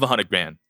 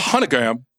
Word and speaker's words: 100 Grand: Love 100 grand. – 100 grand? – It's so good. – How did you pronounce it? You like 100 gram.